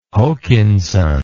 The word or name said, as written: Hokinson